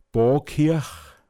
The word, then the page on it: Påtschgåggl: Pinzgauer Mundart